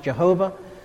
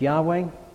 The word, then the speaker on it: Yahweh: traditionally pronounced Jehovah, but we know now that it's more likely pronounced Yahweh, the Lord, when it's written in all capitals.